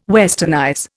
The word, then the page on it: westernize: Indian